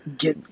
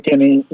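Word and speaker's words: Guinee: Guinea. I'm not saying that right. – Tim Guinea. – Yeah.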